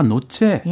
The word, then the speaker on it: nozze: 아, 노체. – 예. – 노체, 노체. – 좋아요. 어, 발음 좋은데요.